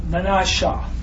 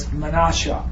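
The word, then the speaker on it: Menashe: And actually, Manasseh's name is pronounced. Let me see. Oh yeah, Menasheh. So it's the last of it's like spelled S-H-E-H instead of just S-E-H. So it's Menasheh.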